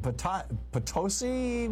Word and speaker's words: Potosi: Potosi, Missouri. Am I saying it right? – That is correct.